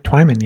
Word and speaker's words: Twyman: Twyman, yeah. Okay. English.